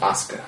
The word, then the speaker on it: Asuka: Yeah, it's pronounced Asuka. – Asuka. Yeah, but it says Asuka. – Well, that's how you spell it.